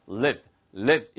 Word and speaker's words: live: 길게 발음하시면 떠나다는 뜻이니까 L-E-A-V-E, leave고요. 얘는 live, live 이렇게 발음하시는 게 좋습니다.